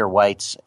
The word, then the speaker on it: Weitz: I'm going to introduce him in just a moment, but make sure you follow me on Twitter at Peter Weitz and on my blog, In Black and Whites. Weitz is spelled W-E-I-T-Z.